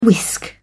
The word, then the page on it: whisk: British English